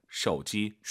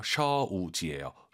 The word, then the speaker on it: 手机: 要学习汉语姐姐喝咖啡哥哥买 쇼지, 쇼지가 아니라 오유는 어우예요. 쇼우지예요. 쇼지, 쇼지, 쇼지 하시면 안 됩니다.